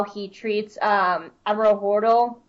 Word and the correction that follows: Holdo: Oh, I can never say her name. – Holdo. – Thank you. I can never pronounce her name right.